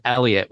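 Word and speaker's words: Elliott: And like for my last name's Elliot with two L's and two T's, but people spell it with one T all the time.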